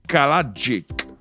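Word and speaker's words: Kalajdžić: Radivoce Kalajic. Radivoce Kalajic. C'est, je, vais vous appeler son nom de famille, ok? K-A-L-A-J-D-Z-I-C. Faut que je prononce J-D-Z, ok?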